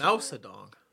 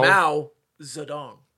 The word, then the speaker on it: Mao Zedong: Mao Zedong. – That's how it's pronounced. Mao. – Mao Zedong.